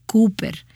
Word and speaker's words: Cowper: Ahora, el nombre se pronuncia Cowper, pero no lo sabrías con solo mirar cómo se escribe. Esta es la forma en que es pronunciado en Inglaterra.